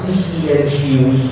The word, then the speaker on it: dengue: We should research the ramifications of using genome engineering to control organisms such as mosquitoes that can spread malaria, or how do you pronounce dengue fever? – Dengue fever.